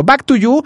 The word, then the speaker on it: Back 2U: Así se escribe Back 2U, pero pues me diría Natalie, eh, pronúncialo bien. Back to You, Walk to the Moon con Steve Aoki.